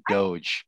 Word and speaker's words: Doge: Was a lot of people are calling it doggy coin, but it's actually Doge.